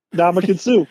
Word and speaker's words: Ndamukong Suh: Ndamukong Suh. – Yep. – Ndamukong Suh.